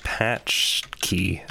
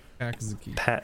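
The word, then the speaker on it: pączki: It's like patch key. That's how I remember it. – Patch key.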